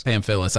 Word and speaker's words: Pamfilis: Pamphilis.